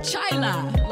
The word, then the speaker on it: Chila: So that's Chila, like chai tea, but with a la at the end. Chila, like China.